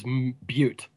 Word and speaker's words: Butte: Gene is the Bozeman to Amanda's Butte.